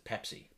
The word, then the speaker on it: Pepsi: I think they used to pronounce it Pepsi. – Pepsi. – They would put the emphasis on the pep.